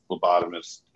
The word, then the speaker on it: Phlebotomist: Maybe you want to start out, you know, something small, you know, the certificate program on the phlebotomist or did I say that right? Phlebotomist. – Yeah. – Yep. There we go.